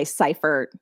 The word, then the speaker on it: Seiffert: It's a long I, Seiffert. – Seiffert. Okay. – Seiffert.